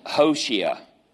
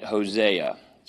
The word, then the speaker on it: Hosea: Hosea. All right, Hosea. What we say. We give it a Spanish twist, don't we?